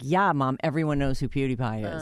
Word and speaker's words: PewDiePie: In fact, I think I said his name wrong, and one of our interns was like, oh, you old guy. It's PewDiePie, dummy.